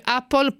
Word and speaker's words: Apple: All'americana si dice Apple, potete lamentarvi quanto volete, chiedete alla nostra Giulia Crivelli che è assolutamente un'autorità in materia.